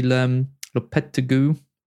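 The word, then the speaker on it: Lopetegui: See Lopetegu, that's how you pronounce it.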